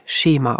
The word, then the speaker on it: Schema: Im Folgenden wird das Fachwort Schema zur besseren Verständlichkeit Schema ausgesprochen. Dein Kind nutzt Gegenstände auf eine bestimmte Weise, um dadurch die Beschaffenheit der Welt zu verstehen.